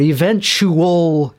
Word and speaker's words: eventually: I love when people add an extra syllable to that word. Eventually. No, it's eventually. It's four syllables.